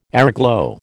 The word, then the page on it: airglow: American English